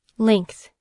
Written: length

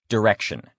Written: direction

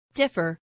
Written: differ

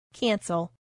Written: cancel

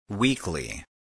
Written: weekly

